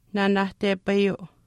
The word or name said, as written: naanaahtepayuu